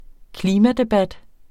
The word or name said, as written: klimadebat